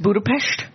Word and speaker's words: Budapest: Is it pronounced Budapest? – Yeah.